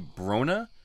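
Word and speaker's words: Bronagh: So I'm going to say Brona is how you pronounce it.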